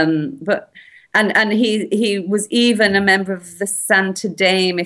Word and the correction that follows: Santo Daime: That's Santo Daime and the UDV, or Uno de Vegetal, are both ayahuasca churches.